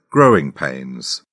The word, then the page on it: growing pains: British English